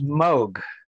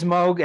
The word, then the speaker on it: Moog: But very first thing is it is pronounced Moog. – It is. – No, it is Moog. And I asked Bob Moog himself, Robert Moog, when I met him and went up to his place, how do you say it? And he said, it's Moog.